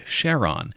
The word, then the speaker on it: Charon: Charon was the first known. Some people pronounce it Charon.